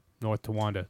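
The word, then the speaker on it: North Tonawanda: Troopers found the 60-year-old Robert Brandle of North Tonga. This can't be New York. That's unpronounceable. Tawanda. North Tawanda. There we go. I got it. I sounded out.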